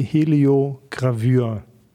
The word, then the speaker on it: Heliogravur: Das ist die Heliogravur.